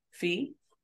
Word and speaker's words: Phea: Fee. That last name is spelled P-H-E-A if you happen to be looking it up.